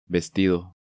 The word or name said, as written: Vestido